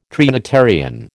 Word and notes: Trinitarian: American English

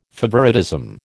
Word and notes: favouritism: American English

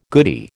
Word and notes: goodie: American English

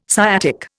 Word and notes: sciatic: Indian

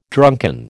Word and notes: drunken: American English

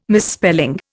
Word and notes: misspelling: Indian

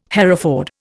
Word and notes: Hereford: Indian

